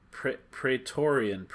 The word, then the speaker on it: Praetorian: I think that's pronounced differently? – I think it's Praetorian. P-R-A-E. – That sounds a little better. – T-O-R-I-A-N, Praetorian Guards.